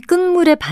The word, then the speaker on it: 끝물: 발음은 끝물이지만 첫 번째 음절의 받침은 티읕이고요. 이와 비슷한 것으로 마의 기억 받침을 쓰는 막물도 있습니다. 그렇다면 끝물의 반대말로 그 해에 맨 처음에 나는 것을 뜻하는 표현은 첫물일까요?